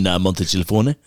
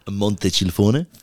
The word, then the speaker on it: Montecilfone: In Montecilfone. That's how you say it. – Where? – Montecilfone.